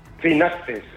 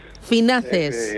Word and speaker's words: Finaces: Eh, oye, ¿cómo lo, pronunciáis? Eh, ¿Finaces con acento de la E o Finaces con acento de la A? – Finaces. – Finaces. Eh, eso es.